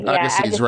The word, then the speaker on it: Agassiz: I always mispronounce his name. – Agassiz, right.